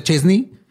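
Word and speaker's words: Szczęsny: O sea, se pronuncia Chesney, pero no se escribe S-Z-C-E-Z-N-I. O sea, son 7 letras, una vocal.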